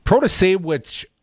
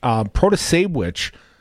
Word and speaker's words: Protasiewicz: I don't think that they see the Protasiewicz, pronounced like witch, Protasiewicz is, if anything, is probably the party choice.